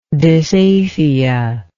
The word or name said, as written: dysaphia